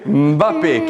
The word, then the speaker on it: Mbappé: non lo pronuncio bene, ma si chiama Mbappé. – Mbappé, Kylian Mbappé.